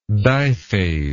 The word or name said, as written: diphase